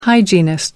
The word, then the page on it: hygienist: American English